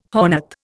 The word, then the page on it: hornet: Indian